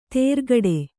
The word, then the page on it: ತೇರ್ಗಡೆ: tērgaḍe